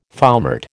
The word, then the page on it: foumart: American English